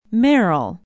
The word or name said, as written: merrill